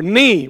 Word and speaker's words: knee: So, or knee with a K that you don't pronounce.